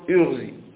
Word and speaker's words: اغزي: فتقول إذن عندما تريد أن تنطق بهذا الفعل على هذه الصفة فتقول اغزي اغزي اغزي، هذا معنى قوله بكسر مشم الضم، بكسر مشم الضم، كأنك تبدأ بالكسر أولا ثم لا تمكن لها وتخلط عليها حركة الضمة، فيحصل لك ما يسمى ماذا بي؟